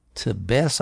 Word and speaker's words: Tebes: Wikipedia is relying on a scholar named Juan Manuel Tebes, I think is how you would pronounce his name, T-E-B-E-S, a 2022 article, Edom and the Southern Jordan in the Iron Age, quoted in the Ancient Israelite World, edited by George A.